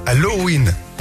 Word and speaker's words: Halloween: Eh non, Halloween. On prononce le haut quand même.